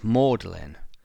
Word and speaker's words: Magdalen: It's written as Magdalen, but pronounced Magdalen.